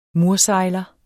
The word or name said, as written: mursejler